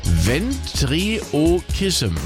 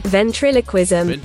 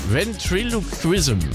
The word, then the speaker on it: Ventriloquism: Das ist die Ventriokism. Und da habe ich extra was vorbereitet, weil ich das selbst gar nicht aussprechen kann. Also ich meine das hier. – Ventriloquism. – Ventriloquism. – Ventriloquism. – Ventriloquism. So, das ist Bauchrednerei.